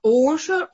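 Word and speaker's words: ошер (עושר): Ошер – наполнение э, духовное, а ошер – о, вот так, о, гортанное – это произношение э, уже э, э материальных каких-то благ.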